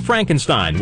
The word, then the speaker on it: Frankenstein: Mel Brooks' Young Frankenstein was the big comedy hit.